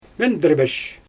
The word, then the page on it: mendIrbesh: Xyzyl